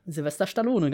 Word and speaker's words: Sylvester Stallone: Sylvester Stallone, ganz genau. So wird der nämlich richtig ausgesprochen. Das wissen, ja, das wissen nur die meisten nämlich nicht.